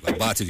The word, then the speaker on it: Abate: É Abate. Mas é um sobrenome italiano, mas em português pode pensar alguma coisa de abatedor.